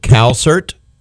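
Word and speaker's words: Calsert: Once again, her name is Kina, and you said you pronounce it Calcert? – Yeah, Cal and then S-E-R-T. – S-E-R-T.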